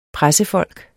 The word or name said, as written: pressefolk